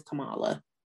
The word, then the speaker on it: Kamala: How do I say her name? – I believe it is Kamala.